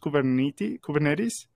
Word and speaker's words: Kubernetes: It's pronounced Kubernetes.